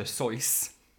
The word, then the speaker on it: Seuss: I mean, it's actually supposed to be pronounced Dr. Seuss. – Seuss. – That's the German kind of way.